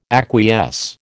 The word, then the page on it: acquiesce: American English